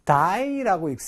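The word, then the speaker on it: dye: die라고 읽습니다. 죽다의 die와 발음이 똑같습니다.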